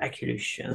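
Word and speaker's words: Akliouche: Akiluche. I always say that wrong. The Monaco midfielder. He also came on. – Akinuş. – Akiluche, thank you.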